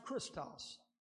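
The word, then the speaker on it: Christos (Χριστός): The Greek word is pronounced Christos. That's the Greek word, Christos.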